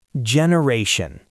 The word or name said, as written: generation